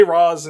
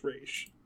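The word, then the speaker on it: Ra's: And they say Roz in the... – But it's R-A apostrophe S. – Yes, but they say Roz in Batman Begins, but I'm pretty sure the actual proper pronunciation is Raish.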